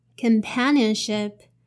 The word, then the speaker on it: companionship: Separate the word into four parts. C O M P A N I O N S H I P. So in the first part, the pronunciation of vowel O here is a. Uh, uh, so the first part is con, con.